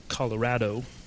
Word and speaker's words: Colorado: Now, growing up in Colorado, and yes, it is pronounced Colorado, my family lived in a house that was on top of a very large hill.